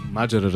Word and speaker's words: Маджера: Абе, верно ли Маджера е правилното ударение? – Разбира се. – Маджера, разбира се.